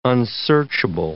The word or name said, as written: unsearchable